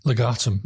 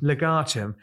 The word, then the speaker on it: Legatum: Is it pronounced legatum or legitum? – Legatum. – Legatum.